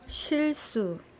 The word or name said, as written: silsu